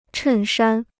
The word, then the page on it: 衬衫: chèn shān